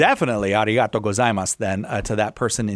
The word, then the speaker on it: arigato: Arigato for that very much. Did I say that correctly, Jimmy? I know you can say it. – Yeah. – Arigato. – Arigato.